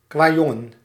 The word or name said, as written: gamin